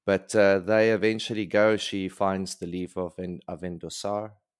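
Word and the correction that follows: Avendesora: and... – Avendosaura. – That's the one. – Avendosaura. – That's exactly how you say it, Billy.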